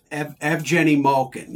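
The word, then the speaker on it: Evgeni Malkin: Malkin gets it twice because in Russia, he is Evgeny Malkin. – That's how you pronounce it in his native tongue? – Yes. Evgeny Malkin, yes.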